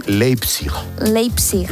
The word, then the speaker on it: Leipzig: ¿Lo he dicho bien? – Qué importante saber idiomas. – Tengo aquí a mi amigo que me lo dice. ¿Cómo se dice? – Leipzig. – Leipzig. – Leipzig. – Leipzig, ¿ves? – Leipzig, muy bien.